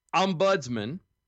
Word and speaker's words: ombudsman: Ombudsman. – Uh huh. – Is how you enunciate it. – I did say it right.